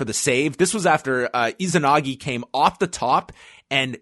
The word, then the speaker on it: Izanagi: Is that how you pronounce it? – Izanagi.